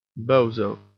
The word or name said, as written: bozo